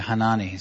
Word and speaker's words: Hanani: Is that how you say his name? Hanani? – Dem Bruder Hanani. – Yeah, Hanani is good.